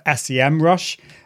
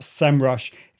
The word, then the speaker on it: SEMrush: I always used to call it SEMrush, search engine marketing, but it is pronounced SEMrush.